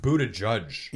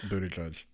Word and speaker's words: Buttigieg: I think it's pronounced Buttigieg. – Yeah, it is Buttigieg.